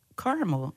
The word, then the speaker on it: caramel: And me and her like was going back and forth because one of the words was caramel, and she's like, "It's not caramel, it's caramel," and I'm like, "It's caramel."